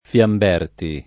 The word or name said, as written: Fiamberti